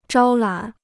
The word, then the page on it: 招揽: zhāo lǎn